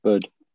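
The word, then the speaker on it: Buddh: Bud. – The Bud International Circuit.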